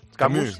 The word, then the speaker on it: Camus: Porque killing al árabe está basada en la obra de Albert Camus, ¿se dice así? – Camus.